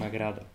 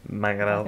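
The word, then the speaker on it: m'agrada: Magrado. – È un A, mi agrada. – Però come si pronuncia come una O. – Magrado.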